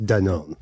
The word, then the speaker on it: Danone: It's a French company. It's pronounced Danone.